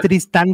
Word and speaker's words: Tristán: ¿Cómo es Tristan o Tristán? Es con acento, ¿verdad? Este, eh, creo que es Tristan, pero no sé. A ver, búscale. – búscale. No, sí es ah. Tristán con acento en la A.